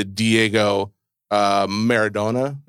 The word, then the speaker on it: Maradona: And the second most, before this, the most high selling piece of memorabilia was the Diego Maradona. Is that how you pronounce it? – It is.